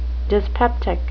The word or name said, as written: dyspeptic